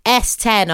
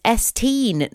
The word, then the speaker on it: S10: Apparently, S10, our lovely postcode Dutch artist, apparently you pronounce her name as S-teen, not S10, because the Dutch word for 10 is teen, T-I-E-N.